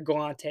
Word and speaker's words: Galante: Anyways, I've been big into zoology, hence the cards, and I've been watching like Forrest Gallant will be on Joe Rogan, and then he also does his own shit. Or Galante is actually pronounced his name.